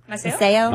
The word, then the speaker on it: Maceo: How do you say M-A-C-E-O? – Maceo? – Maceo.